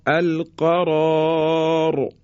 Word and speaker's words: القرار: پس بنابراین دو تا حرف رای ما در اینجا تفخیم و درشت تلفظ میشه. تکرار میکنم، القرار.